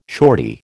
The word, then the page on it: shorty: American English